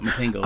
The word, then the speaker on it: Matango: M-A-T-A-N-G-O. So if it's still supposed to have a Japanese pronunciation, obviously it would be Matango.